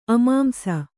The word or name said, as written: ಅಮಾಂಸ